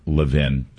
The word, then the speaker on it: Levin: It's pronounced Mark Levin, Chuck Grassley, or Media Research Center or some of these other right-of-center outlets.